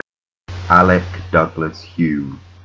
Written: Alec Douglas-Home

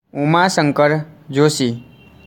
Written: Umashankar Joshi

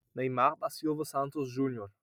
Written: Neymar

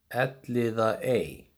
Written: Elliðaey

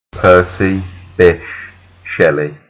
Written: Percy Bysshe Shelley

